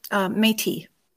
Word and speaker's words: Métis: A descendant of kings, pilgrims, Ojibwe hunters, and Red River Métis. Did I say that correctly? – Métis.